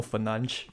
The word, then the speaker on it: Fernandes: By the way, it's pronounced as Bruno Fernandes.